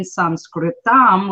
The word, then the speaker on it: Sanskritam: And the technical term in Sanskritam, which is how you say Sanskrit in Sanskrit, is through spoken words.